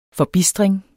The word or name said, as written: FORBISTRINGEN